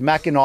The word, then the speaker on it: Mackinac: Yeah, I said it wrong. It's Mackinac Island, apparently.